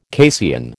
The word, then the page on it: casein: American English